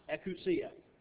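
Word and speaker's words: exousia: There is one that is pronounced ekousia, and it's the power of authority.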